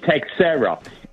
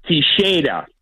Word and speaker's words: Teixeira: And in the Portuguese language, in Portugal, it's pronounced Teixeira. – Teixeira. – Well, Teixeira. – So, I like that. – And in America, it's Texera. Every time he came up to bat, me and my friends would say, they're pronouncing it wrong. It's Texera. – Well, wait a second. But you just said the Portuguese pronunciation. – John Sterling, I understand. – In Portugal, you say it Teixeira. In America, you say Texera.